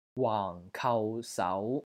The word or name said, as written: WAANG4 KAU3 SAU2